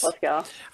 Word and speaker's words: Cobleskill: She lives with her husband and 3 kids in upstate New York, serves as a youth pastor at Fusion Community Church in Cobleskill. Did I say that right, Pauline? Is that right? How do you say it? – Yes, that's right. – Yes. Cobleskill.